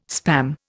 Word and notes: Spam: Indian

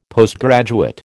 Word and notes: Postgraduate: American English

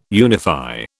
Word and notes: unify: British English

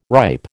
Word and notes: ripe: American English

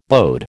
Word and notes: bode: American English

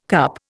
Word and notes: CUP: Indian

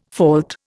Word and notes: fault: Indian

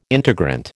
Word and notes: integrant: American English

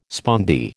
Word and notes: spondee: American English

